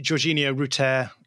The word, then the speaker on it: Georginio Rutter: Well, I'm going to start off by correcting your pronunciation. – Go on then. – Which is annoying, but I guess it's good to get on these things early enough. But yeah, I would say Jorginho Rutter.